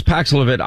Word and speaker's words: Paxlovid: The doctor was very pompous with me, was pushing me for the Pavlovix, however you pronounce that. – It's Paxlovid. I'm not against Paxlovid.